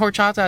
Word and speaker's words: horchata: Isn't horchata a type of dance as well? – No. – I'm just kidding. I knew. It just rhymes with bachata.